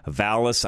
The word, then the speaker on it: Vallas: Paul Vallas. Make sure I say that correctly. Vallas, V-A-L-L-A-S, and Brandon Johnson.